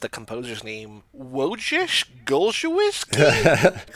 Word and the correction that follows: Wojciech Golczewski: Close. It's uh, Wojciech Golczewski.